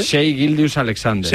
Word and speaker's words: Shai Gilgeous-Alexander: Shai Gilgeous-Alexander.